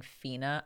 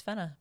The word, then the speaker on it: Fena: I think just being an American, my mind automatically assumed that it would be pronounced Fina because of Xena Warrior Princess. – I yeah, I just like it, it, Just my brain just thought that was the logical way to pronounce it as a, a Westerner, but they stick true and they keep it Fena, just like the um, the Japanese sub does. So yeah, it's Fena, Pirate Princess.